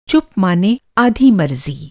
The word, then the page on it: मरज़ी: مرضی